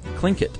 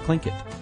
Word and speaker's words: Tlingit: Apparently it's pronounced Tlingit. And we had people write in from Alaska. Matthew's from Seattle, Washington. He wrote in and says it's pronounced Tlingit.